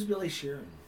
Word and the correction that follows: Billy Sheehan: Did I say his name wrong? – I don't know. – Bass player. – Billy Sheehan? – Billy Sheehan, yeah, sorry.